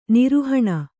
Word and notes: ನಿರೋಹಣ: Kannada

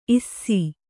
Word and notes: ಇಸಿ: kannada

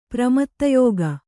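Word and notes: ಪ್ರಮತ್ತಯೋಗ: pramatta yōga

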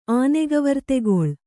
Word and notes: ಆನೆಗವರ್ತೆಗೊಳ್: Kannada

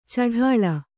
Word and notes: ಛಢಾಳ: Kannada

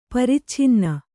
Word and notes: ಪರಿಚ್ಛಿನ್ನ: paricchinna

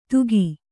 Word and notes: ದುಃಖಿ: duhkhi

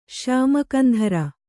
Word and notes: ಶ್ಯಾಮಕಂಧರ: kannada

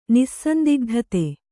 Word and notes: ನಿಸ್ಸಂದಿಗ್ಧತೆ: Kannada

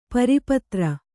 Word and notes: ಪಾರಪತ್ರ: kannada

